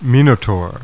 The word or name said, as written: Minotaur